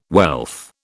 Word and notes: wealth: British English

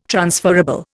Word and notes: transferable: Indian

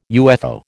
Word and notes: UFO: American English

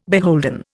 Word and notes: beholden: Indian

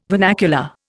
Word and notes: vernacular: Indian

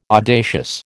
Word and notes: audacious: American English

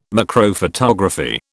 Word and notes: macrophotography: British English

